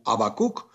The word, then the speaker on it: Habacuc: en el hebreo bíblico, ya habíamos hablado del Habacuc, y también en el latín bíblico se habla de la misma manera, pero no se pronuncia, ni siquiera se aspira la primera letra. Esto es, se le expresa como Habacuc o se le expresa gráficamente como con H, pero se vuelve a decir y vuelve a sonar fonéticamente como Habacuc.